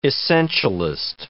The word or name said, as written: essentialist